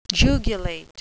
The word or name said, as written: jugulate